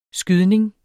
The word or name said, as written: skydning